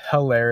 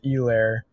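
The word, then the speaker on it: Helaire: It's not Hilaire, it's Elair, but you don't have to scream the E every time, Scott Van Pelt.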